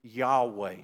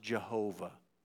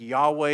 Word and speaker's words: Yahweh: that's the Hebrew word Yahweh, sometimes pronounced Jehovah. I think Yahweh is, most scholars agree, the better pronunciation of that word.